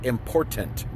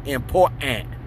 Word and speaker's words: important: to see that a word like important has a T in the middle of it, and pronounce that T. The word is important, my friends. It's not important. Important is not a word.